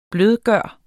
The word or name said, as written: blødgøre